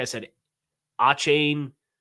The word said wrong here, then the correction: Achane: in like March and got corrected to A Chain, and now I've been saying A Chain, and he was like, actually, it's pronounced A Chan.